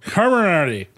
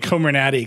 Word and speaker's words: Comirnaty: Is it supposed to sound like immunity? – Yes, it's supposed to sound community with mRNA in the middle. – Yeah. – Oh, no. – I've been pronouncing it Comirnaty because I think they should market it as Natty Boost and like give it away at frat parties.